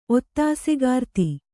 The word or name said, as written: ಒತ್ತಾಸೆಗಾರ್ತಿ